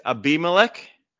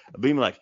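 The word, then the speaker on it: Abimelec: But at 101, I just learned how to pronounce this and now I screwed it up, but it's Abimelech I think that's right. Yes, that's right. Scared when I was about to say it. Abimelech. It's a biblical name. Abimelech yes.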